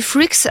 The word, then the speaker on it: FRKS: J'ai lu Freaks avec les voyelles, mais en vrai c'est FRKS. Est-ce que c'est bon comme prononciation ? – Oui, c'est exactement ça, FRKS pour Freaks.